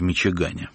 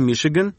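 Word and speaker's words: Michigan: В передаче, посвященной 25-летию издательства «Ардис», вы слушаете мемуарное эссе Алексея Цветкова «У нас в Мичигане». – Воздадим адептам географии. Мичиган, произносится Мишиган, это два полуострова, стиснувшие великие озера с севера и юга.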